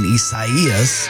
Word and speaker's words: Isaias: Survived the light rain of Hurricane Isaias here in the Midlands.